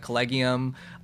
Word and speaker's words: Collegium: And I hear so many, like, that's actually how the brand's pronounced, everybody. Everyone's like collegium, collegium. It's so funny. People still don't know how to pronounce it.